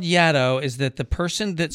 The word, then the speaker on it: Yaddo: Yaddo is a neologism invented by one of the Trask children and was meant to rhyme with Shadow.